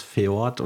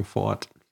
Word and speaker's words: Ford: Und ich habe halt als äh, lese anfänger habe ich das als E gelesen und mich gefragt, warum schreibt man es Feord und sag, alle sagen Ford.